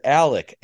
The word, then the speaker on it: Alex: Because I watched the documentary on Netflix, and he refers to himself as Alec, A-L-E-C, you would think, but it's spelled Alex.